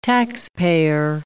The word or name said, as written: taxpayer